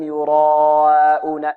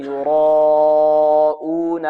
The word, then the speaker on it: يراءون: فهنا إذن قراءته هكذا خطأ، الذين هم يراءون، يراءون، انظر معي الآن في آخر ألف جاءت ماذا؟ مرققة، وهذا خطأ، لابد أن يفخم الألف مطلقا، يعني ألف ينطق بها في الأربع حركات، طوال الأربع حركات هذه الألف مفخمة إلى أن ينتهي من الألف ويدخل في الهمزة، بعد أن يدخل الهمزة يرقق هكذا.